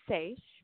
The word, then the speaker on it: Seiche: It's called Seiche. It's a French word, if that helps.